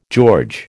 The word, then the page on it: George: American English